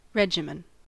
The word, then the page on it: regimen: American English